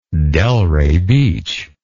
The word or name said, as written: Delray Beach